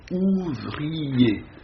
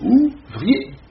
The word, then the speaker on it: ouvrier: Dans la poésie réaliste, un mot qui revient assez souvent, ouvrier, dont phonétiquement, ça compte pour trois syllabes. Voyez-vous. Mais dans beaucoup de poésies réalistes, vous trouvez encore « ouvrier ». Vous voyez, pour deux syllabes.